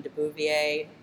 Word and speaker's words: de Beauvoir: There's also a quote I have here from Simone de Beauvoir. Not probably. Am I? Thank you, Laurel. So you did pronounce it right. Anyway, very French. – De Beauvoir.